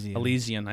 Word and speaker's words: Elysian: Elysian.